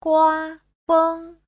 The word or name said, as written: gua1feng1